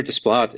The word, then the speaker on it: Desplat: Uh, this time, Alexander Desplat is.